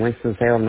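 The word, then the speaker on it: Winston-Salem: Winston, Salem, North Carolina? – You got it right. Winston, Salem, North Carolina.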